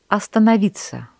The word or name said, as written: остановиться